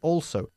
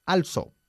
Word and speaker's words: also: Y also, ¿cómo se pronuncia? Se dice also. A pesar de que la gran mayoría de los españoles insisten en decir also.